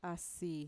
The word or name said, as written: asi